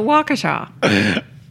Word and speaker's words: Waukesha: No, because the people involved, the people that live there, call it Waukesha.